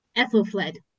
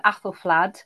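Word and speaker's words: Æthelflæd: I tend to go with Aethelflaed simply on the basis that that A diphthong was how Alfred's name was spelt, and indeed Athelstan.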